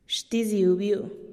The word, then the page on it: xtiziubiu: TdVZ